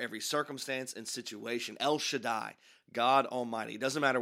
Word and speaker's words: Shaddai: If you put it together, you get Shaddai is what it looks like. We pronounce it Shaddai.